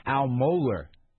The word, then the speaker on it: Mohler: Al Mohler, is that how you pronounce his name? – Yeah.